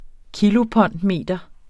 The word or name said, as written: kilopondmeter